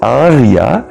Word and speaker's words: Arya: It's Arya, the verse meter. Two long A's. Arya, it's a feminine name denoting a chaste lady.